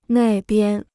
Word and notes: 那边: nà bian

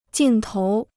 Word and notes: 镜头: jìng tóu

